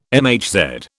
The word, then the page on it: MHz: British English